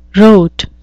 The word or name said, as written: rote